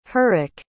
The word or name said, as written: hrycg